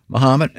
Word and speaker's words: Mahomet: Mahomet, as in Ali.